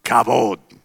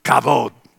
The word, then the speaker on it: kavod: I have what I describe as kavod. Say that with me. Kavod. Let me spell it. In C-A-V-O-D.